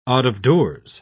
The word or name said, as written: out-of-doors